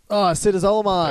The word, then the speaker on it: Acetazolamide: Acetazolamide.